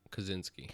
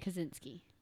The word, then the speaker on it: Kaczynski: Kaczynski? – Kaczynski. – Kaczynski.